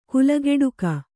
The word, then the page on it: ಕುಲಗೆಡುಕ: kulageḍuka